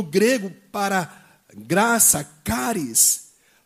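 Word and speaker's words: caris (χάρις): como chariz ou charis, que na verdade se pronuncia caris, ok? Então, que é justamente né, é a base né, ou a raiz da palavra carisma, ok? Então, caris, não é cari não, tá gente? É caris, ok?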